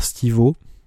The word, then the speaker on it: Stivo: qui est illustré par Stivo. Alors c'est pas le Stivo de Jackass, hein, ça s'écrit STIVO.